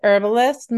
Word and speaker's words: herbalist: Is it herbologist? Do you pronounce the H? – I pronounce it herbalist. Most people, and every now and then the H slides out like herbalist, and I don't know. I just think it has a ring to it.